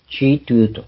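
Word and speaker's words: 基督: 独立学院今でも漢字を使ったの正式名にしていますが、既得と書いて、チートゥーと中国で発音するわけ。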